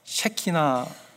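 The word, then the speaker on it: Shekhinah: 여러분 시카이나 글로리라고 들어보셨어요, 그죠? 시카이나 글로리. 한국말로는요, 셰키나 발음을 잘해야 됩니다. 셰키나 영광이라고. 근데 여러분 이것이 성경에 나오는 단어가 아닙니다.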